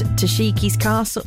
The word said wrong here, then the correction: Takeshi's Castle: That's Takeshi's Castle.